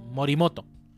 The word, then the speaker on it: Morimoto: el programador este Moritomo, si se pronuncia así, ¿no? Morimoto.